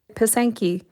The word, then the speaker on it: Pysanky: Pysanky.